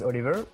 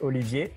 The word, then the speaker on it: Olivier: My name is Oliver. It's like Olivier in French, but Oliver for English people.